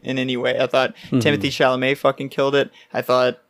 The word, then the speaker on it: Timothée Chalamet: That's how you say his name, right? – Yeah, Timothée Chalamet.